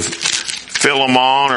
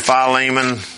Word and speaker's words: Philemon: All right, and then turn over to Philemon or Philemon. I've heard it pronounced both ways.